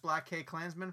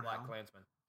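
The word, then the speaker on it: BlacKkKlansman: I didn't think it was actually meant to be like pronounced. I think it's just Black Klansman.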